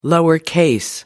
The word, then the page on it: lowercase: British English